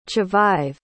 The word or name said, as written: chavive